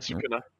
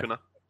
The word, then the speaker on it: Sukuna: Sukuna. – Okay. – Sukuna.